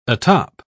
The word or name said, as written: atop